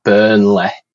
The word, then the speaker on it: Burnley: rather than it being like L-E-Y we pronounce it L-E-H so it's like Burnley that everyone everyone mugs me off for